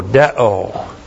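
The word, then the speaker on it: deo (δέω): D-E long O, deo. Deo is called an impersonal verb.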